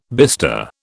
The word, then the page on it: bister: British English